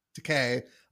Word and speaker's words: Takei: I always pronounce his last name wrong. George Takei? Is it Takei? Takei.